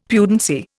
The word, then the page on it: pudency: Indian